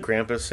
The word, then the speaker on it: Krampus: I think that's how you pronounce it. – Krampus, yeah.